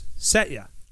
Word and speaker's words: Satya: Why'd you add an I? It's just Satya.